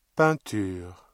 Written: peinture

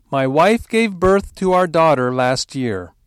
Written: last year

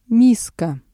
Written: миска

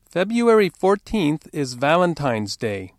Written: February

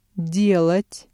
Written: делать

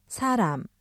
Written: 사람

